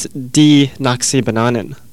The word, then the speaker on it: Die Nakasi Bananen: D-I-E is pronounced D in Dutch, and I keep pronouncing it Die because it looks like Die.